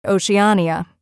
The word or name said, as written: Oceania